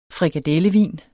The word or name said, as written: frikadellevin